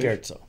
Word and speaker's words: scherzo: For an example of what pizzicato strings can really do, see Tchaikovsky's Fourth Symphony, Third Movement, Scherzo, is that how you pronounce it? – Scherzo. – Scherzo. I knew that.